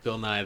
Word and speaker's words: Bill Nye: Sound like you said Bill Nye. – Bill Nye, the science guy. You know, it's what it is. I said it right.